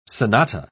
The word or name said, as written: sonata